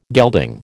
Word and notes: gelding: American English

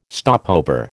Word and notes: stopover: American English

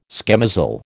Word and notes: schemozzle: American English

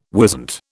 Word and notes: wisent: British English

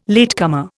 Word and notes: latecomer: Indian

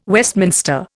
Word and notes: Westminster: Indian

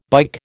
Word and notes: bike: American English

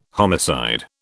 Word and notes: homicide: British English

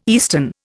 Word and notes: eastern: Indian

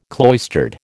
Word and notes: cloistered: American English

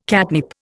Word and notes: catnip: Indian

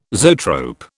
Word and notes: zoetrope: British English

